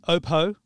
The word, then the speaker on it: Oppo: who is the Australian head of Oppo. Did I pronounce that correctly, Michael? – You did.